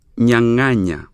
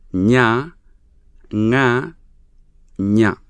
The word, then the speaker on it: nyanganya: Nyanganya. It has three syllables. Nya, nga, nya. Nyanganya.